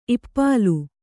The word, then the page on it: ಇಪ್ಪಾಲು: ippālu